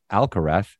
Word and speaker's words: Alcaraz: And Alcaraz, well, if you were from Latin America, it would be a hard Z. But if you're Spanish, the Z is TH, and he pronounces it Alcaraz. So that's how I said it.